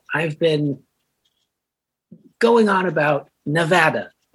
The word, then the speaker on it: Nevada: It's Nevada. Like, sad.